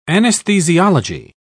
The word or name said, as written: anesthesiology